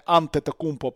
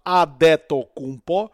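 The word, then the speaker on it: Адетокунбо: Правильно, не Антетокумпо, правильно Адето Кумпо що перекладається з нігерійської як король, король, який повернувся з за моря для того, щоб правити.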